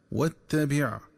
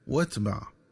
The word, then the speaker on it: واتبع: وكذلك ينبغي التنبه للنطق الصحيح للفظ واتبع، فيقرأ بتشديد التاء مفتوحة وكسر الباء مع حذف همزة الوصل، هكذا واتبع. ومن الخطأ قراءته بتسكين التاء وفتح الباء، هكذا واتبع.